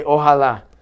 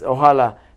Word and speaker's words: Ojalá: When we want to say God willing, Inshallah, we say Ojalá. – I mean, that sounds like Allah in there. – Yes. – The creator. – Yes, Ojalá.